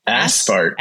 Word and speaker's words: Ausfahrt: Aspart. – Aspart. – Aspart. It's basically, you're like, look, it says aspart. And it's basically exit. It's exit. – It's like A-U-S-F-A-H-R-T, aspart.